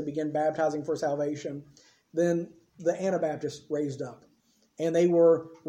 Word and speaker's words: Anabaptists: Me and the girls were talking about this last night, the Anabaptists. They kept trying to pronounce it Anti-Baptist. I said, wait a minute, that means something completely different.